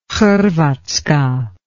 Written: Hrvatska

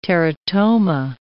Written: teratoma

